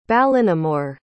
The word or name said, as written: ballinamore